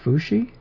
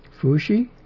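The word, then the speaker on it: Fúxī: It's pronounced Fuxi. Rising and then even tone. Fuxi.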